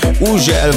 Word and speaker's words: Hugel: Így van, az úriember, akinek minden országon másképp ejtik a nevét. Van, ahol Ugel, van, ahol Hugel, van, ahol Ugel, meg mindenféle kép, de nálunk úgy mondjuk, hogy Hugel, és Salto és a Jamaican Bam Bam érkezik, a csavarjátok fel a hangerőt.